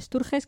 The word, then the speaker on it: Sturges: El director es John Struges. – Más o menos. – Hombre, a ver, como no sabemos cómo se pronuncia, está pronunciada en castellano. – Struges, ¿no? – John Sturges, que también dirigió La Gran Evasión.